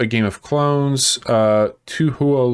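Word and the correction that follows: Touhou: Luna Nights, I think. I think you'd pronounce that as Toho.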